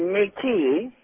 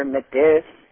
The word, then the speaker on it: Métis: Métis is a pronunciation, or Métis. It's a French term for mixed blood.